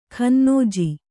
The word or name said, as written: ಖನ್ನೋಜಿ